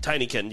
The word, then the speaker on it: Tinykin: Oh, wait, okay, it's Tinykin. – Tinykin, yes.